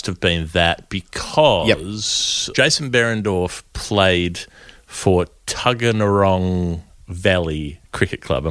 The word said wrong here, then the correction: Tuggeranong: You could probably lose a couple of syllables there. Tuggeranong. That'll do.